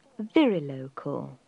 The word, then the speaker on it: virilocal: Now that's spelt P-A-T-R-I-L-O-C-A-L. Patrilocal. A more modern term is virilocal. And we spell that V-I-R-I-L-O-C-A-L. Virilocal. The opposite, when the man moves, is termed matrilocal. And we spell that M-A-T-R-I-L-O-C-A-L.